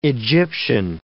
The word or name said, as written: Egyptian